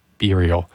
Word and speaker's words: Burial: I was going to pronounce it like Burien, the city just south of Seattle, but it is, in fact, Burial, not Burial.